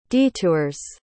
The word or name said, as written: detours